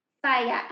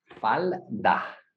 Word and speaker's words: falda: Falla. How do you say it? – Falda.